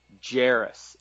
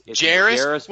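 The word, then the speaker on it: Jairus: Now, it's Jarius. Say it with me, Jarius. – Jarius.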